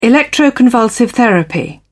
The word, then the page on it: electroconvulsive therapy: British English